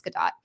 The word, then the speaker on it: Gadot: And I love it, just solidified that Gal Gadot is a fucking wonderful Wonder Woman, though. She's so good. Gadot, I think. I always say her name wrong. It's Gadot. That she's a great Wonder Woman, and she did a fantastic job with what she was given.